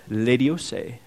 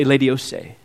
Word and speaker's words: Laodice: He named Laodicea after his wife, Laodice. Say Laodice. Very good. A little accent at the end. I don't know if that's there, but that's how I pronounce it.